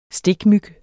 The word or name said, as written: stikmyg